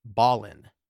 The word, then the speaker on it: Belen: And the name of the town that I mispronounced is spelled B-E-L-E-N. I pronounced it Belen, and I believe it's Ballin.